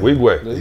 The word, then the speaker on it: Nwigwe: Is that how you say his name? Wigway? – Wigway. – You got it. Toby. – Wigway, yeah. – You got it. That's his last name. – Yeah, right. Come on. N-W-I-G-W-E.